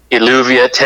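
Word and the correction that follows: Eluveitie: is that how you say it? Illuviate? – Elvati. – Elvati.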